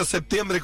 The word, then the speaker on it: septiembre: Dice, amo su país y el acento, pero en el mundo entero se pronuncia septiembre con P.